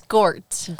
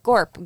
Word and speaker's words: Gort: So it's Gort. – Gort with a T. Yeah. All right. No, because like at the beginning, my notes were like Gorp, Gorp. And then later in the movie, they like pronounce it better. I'm like, wait, his name is Gort. – I always thought it was Gord.